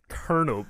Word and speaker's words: Colonel: Yesterday, I talked about the famed Colonial Blood. – Yeah. – It's actually pronounced Colonel Blood. And Colonel is spelled with an L.